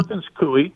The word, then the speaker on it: Perkins Coie: Yeah, Perkins Coie.